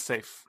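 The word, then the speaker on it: Saief: So, Safe? – But yeah. – That's how you say it? – Safe. Yeah. I thought it was Saif, but it's Safe.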